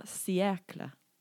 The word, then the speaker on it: siècle: Un siècle. Siècle. – Could you spell it, please? – In French or English? English? – English, please. – S-I-E-C-L-E. Siècle. You want a sentence? – Wait. S-I-E-C-L-E. Cycle, like a bicycle? – Similar to cycle, yeah. S-I-E-C-L-E.